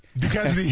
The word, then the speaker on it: Duquesne: From the guy who couldn't pronounce, and I still can't pronounce the Duquesne or whatever university. – Duquesne.